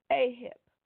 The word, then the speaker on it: aheb: The usage of the word love in this passage is the Hebrew word aheb. I'm trying to, make sure I pronounce it right. A-H-E-B, okay?